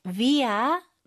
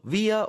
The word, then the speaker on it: via: 네, via 명사 혹은 via라고도 발음하는 분들도 있는 것 같아요. – 맞아요. So, via or via 명사.